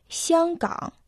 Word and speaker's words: 香港: Название города Гангунг в китайском языке состоит из двух иероглифов – Сянган – Первый иероглиф «сян» произносится в первом доне, означает аромат, ароматный. Второй иероглиф «кан» произносится в третьем доне, означает залив, бухта.